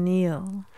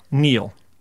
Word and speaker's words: kneel: How are you spelling Neil? – K-N-E-E-L, Neil.